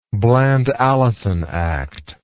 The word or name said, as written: Bland-Allison Act